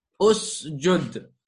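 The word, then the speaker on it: Usjud (اسجد): Because we have a Dhamma on R2, right? That is the reason why we Dhamma there. And this is now pronounced what? It's pronounced as Usjud. Excellent.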